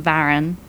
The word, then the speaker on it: Varon: So because I was, you know, demonstrating that on Superlatively Rude, Jamie Varon, who is, I think, actually in America, and you pronounce it Varon, but Jamie Varon, V-A-R-O-N.